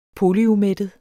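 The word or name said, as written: polyumættet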